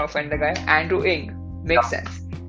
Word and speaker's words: Ng: I just absolutely hate it when I tell people no, it's not Andrew NG. Even I realize actually searched up how to pronounce his surname. It's actually pronounced Ing.